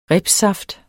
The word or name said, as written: ribssaft